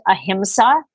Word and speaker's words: ahimsa: It's pronounced ahimsa in Sanskrit, and it translates as nonviolence.